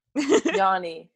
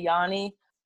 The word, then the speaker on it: yoni: Some people say yoni, some people say yani. It really depends on like your accent. And like, because like depending on your accent, you say that. But like for me personally, I say yoni. It's spelled Y-O-N-I.